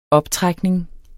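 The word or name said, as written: optrækning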